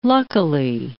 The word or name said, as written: luckily